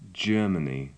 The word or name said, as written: germany